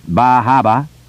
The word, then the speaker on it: Bar Harbor: Camden, Rockland, Bar Harbor, pronounced Bahaba, Wiscasset, Bath, Portland, Ogunquit, the Berwicks.